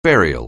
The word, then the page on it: burial: anglais